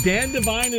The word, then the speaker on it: Dan Devine: I think it's Dan Devine. Devine? – That is excellent. Dan Devine it is.